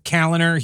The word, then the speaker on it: Kaliner: Pete Kaliner here. That's spelled K-A-L-I-N-E-R. Just in case you were wondering, and no, there isn't a D in the last name, nor C, for that matter.